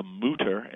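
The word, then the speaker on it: Mütter: And there's a museum in Philadelphia called the Mutter, M-U-T-T-E-R, with the accent on the U, that has a whole gallery display of the odd things that doctors have found inside people.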